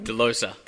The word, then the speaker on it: Delosa: Delosa.